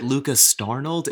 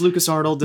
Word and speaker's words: Lucas Arnold: Because they is it Lucas Starnold? Is it Lucas Saint Arnold? I've gotten that. I'm like, no, it's just Lucas Arnold.